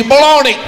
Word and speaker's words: bologna: Bologna! I don't know if you saw, there's a G in the word. – I don't see no G, baloney! – Okay, well, the word does end with an A.